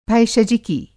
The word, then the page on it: भैषजिकी: Hindi